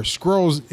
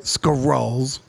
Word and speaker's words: Skrulls: right, were Skrulls in disguise as Russian soldiers. – Say it right. Skrulls.